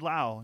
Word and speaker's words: Lowe: Not Brandon Lau, Nate Lowe. – Spelled the same way. That would be tough.